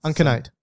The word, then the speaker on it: uncinate: I can't pronounce it. UNC. How do you pronounce it? – I don't know. You gotta spell it for me. – UNCINATE. – Uncinate. – Uncinate. – Yeah.